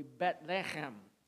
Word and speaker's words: Bethlehem: Anytime you see B-E-T-H, Beth, connected to a word, that's the house of. All right, you have Bethlehem. In Hebrew, the H's are not pronounced, so it would be Bethlehem.